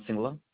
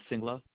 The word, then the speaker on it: Singla: Sajan Singla. – Can you repeat that? – Yes, ma'am. I can spell it for you. First name is Sajan, S as in Sam, A-J-A-N. And the last name is Singla, S as in Sam, I-N-G-L-A.